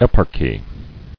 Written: Eparchy

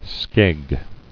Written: Skeg

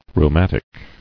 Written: Rheumatic

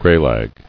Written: Graylag

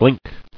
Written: Blinking

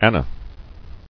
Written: Anas

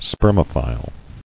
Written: Spermophile

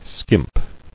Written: Skimping

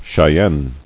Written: Cheyennes

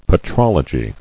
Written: Petrologically

